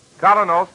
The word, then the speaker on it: Kolynos: That's spelled K-O-L-Y-N-O-S. Colonel's Toothpaste.